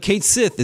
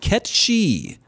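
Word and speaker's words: Cait Sith: The pronunciation of Kate Sith is actually not Kate Sith, but Ketchi.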